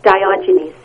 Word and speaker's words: Diogenes: I didn't think I said it right. What is it? – Diogenes syndrome.